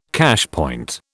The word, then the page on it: cashpoint: British English